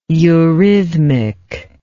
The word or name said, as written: eurythmically